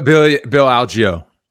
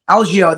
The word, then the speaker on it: Algeo: But then I go to the UFC Apex, and it was that Andre Feely versus Billy Alego. How do you say it? Bill Algeo. – Algeo.